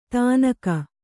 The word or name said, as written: ಧನಕೋ